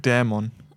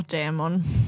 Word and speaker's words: Dämon: Und zwar ist es mir aufgefallen, ich weiß nicht, ob es innerhalb der Folge kam oder generell beim Lesen des Titels, heißt es Dämon oder Dämon? Wie betont man das? – Also ich sag Dämon. – Ich sag auch Dämon. – Ich sag halt auch Dämon der Rache.